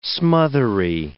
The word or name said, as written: smothery